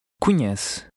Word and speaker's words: conhece: The NH combination is pronounced like the middle of the word onion. Now you read number 9. – Conhece.